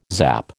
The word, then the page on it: zap: American English